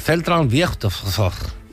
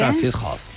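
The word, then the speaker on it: Zentralfriedhof: El Zentral Friedhof. – ¿Qué es? – Que no lo he dicho bien. – Zentral Friedhof, creo que se dice o algo así.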